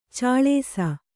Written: ಛಳಿಸು

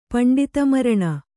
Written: ಪಂಡಿತಮರಣ

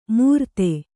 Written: ಮುರ್ತೆ

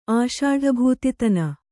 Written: ಆಷಾಢಭೂತಿತನ

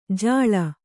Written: ಝೋಳಿ

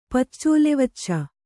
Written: ಪಚ್ಚೋಲೆವಚ್ಚ

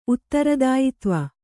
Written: ಉತ್ತರದಾಯಿತ್ವ

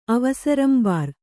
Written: ಅವಸರಂಬಾರ್

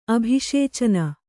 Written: ಅಭಿಷೇಚನ

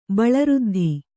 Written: ಬಳಋದ್ಧಿ

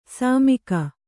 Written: ಸುಮುಖಿ

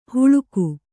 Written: ಹುಳುಕು

